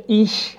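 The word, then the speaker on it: ich: خب ما اینجا اگر بخوایم ضمایر فاعل رو شروع کنیم، همونجور که میبینید ضمیر اول ضمیر ایش هستش. ایش، دوستان توجه داشته باشن، این نکته خیلی مهمه که این حرف نه ش هست نه خ، یه چیزی بین ش و خ هستش و دوستان میتونن راحت تلفظش کنن.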